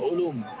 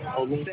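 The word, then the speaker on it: Olum (עולם): I want you to know what is that in Hebrew? Olum. – Olum. Same thing. – Olum. – The only they would spell it O-L-U-M.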